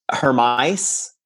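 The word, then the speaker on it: Hermès: I always say this name wrong. Hermes, not Hermes. It looks like Hermes, but it's a Hermes.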